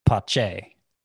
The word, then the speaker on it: Pache: So the way they were pronouncing it on the Braves broadcast was Pache.